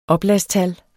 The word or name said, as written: oplagstal